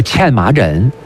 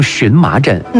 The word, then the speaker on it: 荨麻疹: 这最常见到的一个皮肤病，把它叫茜麻疹。今天大家也把它读成荨麻疹。